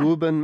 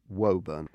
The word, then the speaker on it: Woburn: Woburn, Mass, right. Why is it pronounced Woo? Is there a single O there? – So it should be like Woburn.